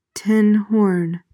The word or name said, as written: tinhorn